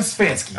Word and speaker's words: Stefanski: Kevin Stefanski.